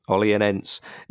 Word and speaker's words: Olhanense: How do you pronounce this one? – Olhanense.